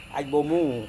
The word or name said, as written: aibo-mu